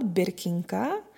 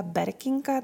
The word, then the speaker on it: Birkinka: Já jsem teda měla tendenci to vyslovovat Birkinka, ale Emily v dabingu říká Berkinka, tak se asi budu řídit podle ní.